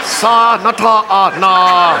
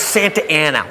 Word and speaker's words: Santa Ana: Sa Nata Na. How? It's Santa Ana. What says Santa Ana? Look, I know I didn't finish school, but that frickin' says Sa Nata Na. They pronounce it Santa Ana.